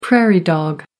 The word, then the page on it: prairie dog: American English